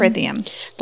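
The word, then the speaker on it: Prythian: And she can either die on the spot or come and live in Prithium. Did I say that right? – Mm-hmm. – Prithium.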